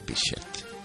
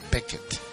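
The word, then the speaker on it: Bechet: El primero es una composición de John Coltrane, Blues to Bichette, y en para Sidney Bichette o Sidney Beckett, depende cómo lo quieran pronunciar.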